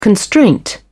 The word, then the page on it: constraint: British English